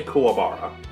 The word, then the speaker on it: Kuwabara: All right, but yeah, as I said, how do you say this? – I say Kuwabara. – Kuwabara, okay.